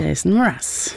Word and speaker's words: Jason Mraz: Jason Mraz.